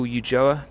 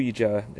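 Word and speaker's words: Ulloa: it is Michael Ujoa. My dad is Spanish and he's from Coruña, so the northwest of Spain in Galicia. Whenever I find someone who can pronounce my name correctly, we're instantly very close friends. So yeah, Michael Ujoa is the way that it should be pronounced.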